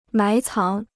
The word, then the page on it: 埋藏: mái cáng